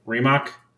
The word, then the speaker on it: Rimac: Is that how you say it? Rimac. Rimac. Yep.